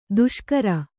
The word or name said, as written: ದಿಶಾಕರಿ